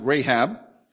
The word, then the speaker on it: Rahab: It says, men and women such as Abel, Enoch, Noah, Abraham, Isaac, Jacob, Sarah, Moses, Rahab, as some would pronounce it, Rahab, Gideon, Barak, Samson, Jephthah, David, Samuel.